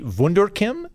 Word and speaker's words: Wunderkim: I purchased an edible hammer from Wunderkin Industries. Is that how you spell it? This is me. It's actually Wunderkim, so think wonderful chemicals. Wunderkim.